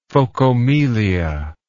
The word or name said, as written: phocomelia